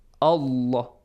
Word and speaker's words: Allah: you gotta pronounce the letter lam of the word Allah or the word Allahumma heavily. How? Allah, Allah, Allah. This way. Allah. Ahdallah. Ahdallah.